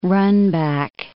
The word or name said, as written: runback